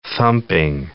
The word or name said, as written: thumping